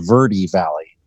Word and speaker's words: Verde Valley: I am in the Verde Valley, or actually it's the Verde Valley. It's spelled Verde and it's named because of the greenness of the area, but they call it the Verde Valley here in Arizona.